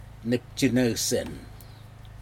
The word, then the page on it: neptinousin: Aneityum